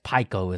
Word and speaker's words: Pico: I guess Pico is the correct pronunciation.